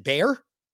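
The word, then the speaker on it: Bayer: Bear, a drug manufacturer that's been around for years all over the fucking world. And she calls it bear. No, it's bear.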